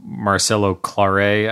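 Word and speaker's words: Marcelo Claure: In that, you know, so Marcelo Claré, I believe, is how you pronounce the Sprint CEO's name.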